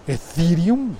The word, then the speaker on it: Ethereum: Se pensaba que después del, Bitcoin, el Ethereum, Ethereum, Ethereum, bien pronunciado, sería el, siguiente nivel, la siguiente generación de, de blockchain que permitiría llegar hasta eso de los contratos inteligentes.